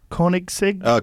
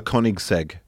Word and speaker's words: Koenigsegg: Koenigsegg? – Koenigsegg.